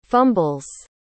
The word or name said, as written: fumbles